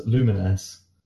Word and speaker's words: Lumines: So, my uni flatmate and I both got PSPs on launch day, and we both got a copy of the game that is pronounced correctly as Lumines.